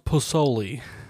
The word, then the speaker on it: pozole: It's pronounced pozole.